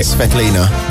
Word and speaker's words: Svitolina: So Wimbledon was on, and there is a tennis player called Elena Svitolina. I'm going to go with. – Yes. – Did I say it right? – Svetlina. – Elena Svitolina. – Svetlina. – Svetlina.